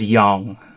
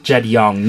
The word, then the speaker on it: Jong: My last name is Yong. – So Jed. – Yeah, J-O-N-G, but pronounce it with a Y. I vex people Jong? – Like that. – You don't do Jed Jong? – Jed Yong.